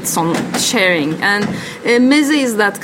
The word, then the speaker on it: meze: Well, maybe you can explain to me in your own words for people who are unfamiliar what the concept of meze is. Am I saying it right? – Yeah.